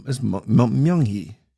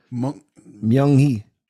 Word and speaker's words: Myung-hee: And like, Mandy or Mei Ling or it's Myung-hee. Myung-hee. Yes.